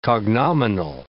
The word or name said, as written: cognominal